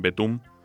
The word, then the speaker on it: Btooom: Sí, se escribe B-T-O-O-O-M con esta exclamación. – Al final, sí. – O sea, se pronuncia Betum, yo siempre que lo he oído me han dicho Betum.